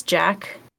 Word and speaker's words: Jac: I won't share my whole name because I don't want to get doxxed, but my first name is Jack, J-A-C,